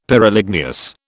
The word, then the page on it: Pyroligneous: British English